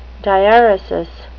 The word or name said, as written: diaeresis